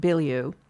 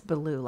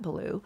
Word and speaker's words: Belew: I've been pronouncing it Belew, but it's Belew, like Cat Belew, art historian and sometime curator.